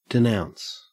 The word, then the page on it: denounce: Australian English